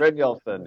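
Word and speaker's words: Brynjolfsson: How do you say that again? – Bryn Nielsen. – Bryn Nielsen. Okay, great.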